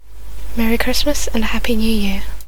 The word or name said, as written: joyeux Noël et bonne année